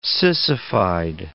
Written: sissified

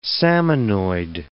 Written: salmonoid

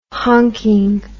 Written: honking

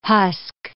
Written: husk

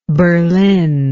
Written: berline

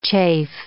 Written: chafe

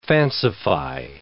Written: fancify

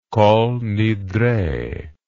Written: kolnidre

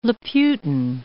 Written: Laputan

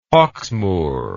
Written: Hawksmoor

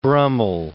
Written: Brummell

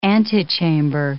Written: antechamber

